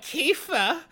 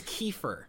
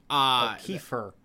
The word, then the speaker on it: Kiefer: She's like, Janelle, you should really stop hanging out with that boy, Kifa. And it's like, oh god, it just grates. His name is Kiefer. – Kiefer. – Okay. Yeah. But she doesn't pronounce it that way. She pronounces it Kifa.